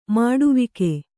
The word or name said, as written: ಮುಡಿವಕ್ಕಿ